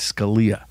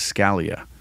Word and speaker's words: Scalia: A last name, the Italian pronunciation of our last name is Scalia. The American version is Scalia.